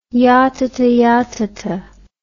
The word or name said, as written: yatatayatata